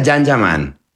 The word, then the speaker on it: Can Yaman: Se escribe Can Yaman, se pronuncia Jan Yaman.